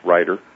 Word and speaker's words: Riter: It's spelled R-I-T-E-R, but it's pronounced Ryder.